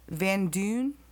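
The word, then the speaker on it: Van Duyn: how do you say D-U-Y-N? – D-U-Y-N? – Van Dune? – Yeah.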